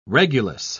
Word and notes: regulus: British English

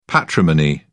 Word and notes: patrimony: British English

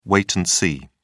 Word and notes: wait and see: British English

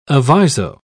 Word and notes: aviso: British English

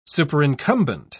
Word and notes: superincumbent: British English

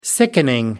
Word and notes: sickening: American English

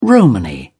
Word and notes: romany: British English

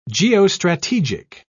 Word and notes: geostrategic: British English

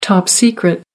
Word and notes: top secret: American English

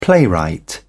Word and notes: playwright: British English

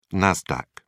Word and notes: nasdaq: British English

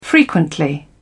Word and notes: frequently: British English

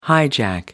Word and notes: hijack: American English

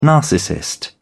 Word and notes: narcissist: British English

